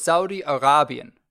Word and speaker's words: Saudi Arabian: Yeah, okay, so it's Saudi Arabian. The emphasis on the Ra.